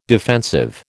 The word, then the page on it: defensive: American English